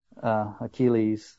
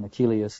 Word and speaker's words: Achilles: Both Lattimore and Fitzgerald use a more Grecian pronunciation. They eliminate the Latin interface. And so I'm going to try to accustom myself to some of these changes. Instead of saying Achilles, saying Achilles and so on.